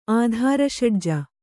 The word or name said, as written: ಆಧಾರಷಡ್ಜ